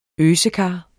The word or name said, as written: øsekar